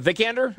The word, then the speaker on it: Vikander: I hear it's a lot of Alicia Vikander. Is that how you say her name? – Vikander? – Vikander.